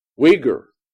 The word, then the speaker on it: Uyghur: What is that? – Uyghur. – Oh, you pronounce it with a yeah. W.